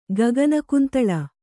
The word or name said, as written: ಗಗನಕುಂತಳ